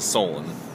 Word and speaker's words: Solon: My name is Solon. – Solon. – Solon? Solon. – Can you spell it? – S-O-L-O-N.